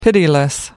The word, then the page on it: pitiless: American English